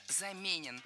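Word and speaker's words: заменён: Вторая ошибка – заменен. Это краткое причастие, и ударение в нем такое же, что и в полном. Замененный – заменен.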